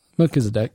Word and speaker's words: Melchizedek: Melchizedek? – Melchizedek, yeah.